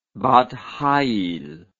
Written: bat chayil

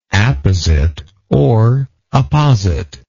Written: appositeness

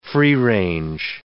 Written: free-range

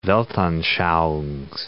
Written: weltanschauungs

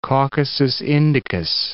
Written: Caucasus Indicus

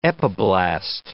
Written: epiblast

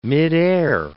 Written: midair

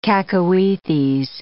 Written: cacoethes

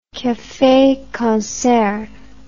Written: cafe concert

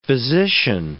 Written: physician

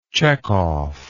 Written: Chekhov

